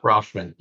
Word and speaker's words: Rutschman: They have this young, double play combo up the middle, and they still got Adley Rutschman. Is that how you pronounce it? – Yeah, Rutschman. Yeah.